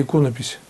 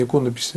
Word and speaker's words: иконопись: В том числе и иконопись. Я намеренно делаю ударение иконопись, потому что неправильно считаю иконопись. Это неправильно.